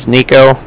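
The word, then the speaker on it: Niko: Can you just let him know, my name is Nico, that's N-I-K-O,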